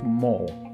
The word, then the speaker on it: mall: que todos llamamos Mo, Mo, M-A-L-L, Mo, Mo. Si se recuerdan esa palabra, se van a acordar de todas estas terminaciones en inglés.